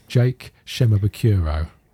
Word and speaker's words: Jake Shimabukuro: but Jake Shemabukuro.